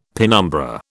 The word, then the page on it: penumbra: British English